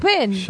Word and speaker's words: Chopin: ¿Cómo se pronuncia? Perdón. – Chopin. – Chopin, perdón.